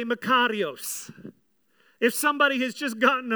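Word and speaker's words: Makarios (μακάριος): Well, in its origin, it is the word makarios. Can you all say that word? Makarios. Just roll your R a little bit. Makarios.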